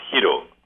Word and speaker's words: Hiro: It's pronounced Hiro. – Hiro, that's right. I had to do it correctly. Hiro. – As opposed to Heroes.